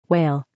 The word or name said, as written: whale